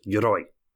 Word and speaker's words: Yoroi: And James says, Hi, can I restore my Daedalus wallet using the Rory wallet? The answer is yes. Of course, I pronounced Yoroi wrong. It's Yoroi. Yes, you can.